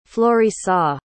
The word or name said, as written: florissa